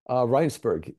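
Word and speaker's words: Rijnsburg: Rheinsberg, yes. It's the Dutch pronunciation.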